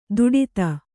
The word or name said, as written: ದುಡಿತ